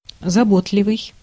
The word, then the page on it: заботливый: /zɐˈbotlʲɪvɨj/